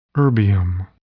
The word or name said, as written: erbium